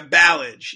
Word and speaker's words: Ballage: Uh, is it Ballage? – Yeah, Ballage. Yeah, that's his name. Yeah, Kalen Ballage.